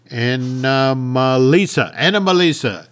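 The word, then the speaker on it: Anomalisa: Wonderful Pete Docter film, but we had when Marnie was there, the Shaun the Sheep movie, A Boy in the World, and Anomazle. Am I saying that name wrong? I'm saying that name wrong. Anna Melissa. – There we go.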